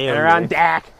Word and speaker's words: Adirondack: Adirondack.